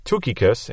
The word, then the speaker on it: Tychicus: Now, Tychicus, or I guess it would be pronounced Tukikus in Greek, means faithful.